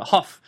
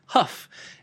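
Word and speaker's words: Hough: And also, it turns out all this time I've been pronouncing the name of the author incorrectly. I've always been saying Jason M. Hoff, but here on Goodreads about Jason M. Hoff, it says Jason M. Hoff, pronounced Huff, is a former 3D artist and game designer, blah, blah, blah.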